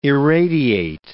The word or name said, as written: irradiate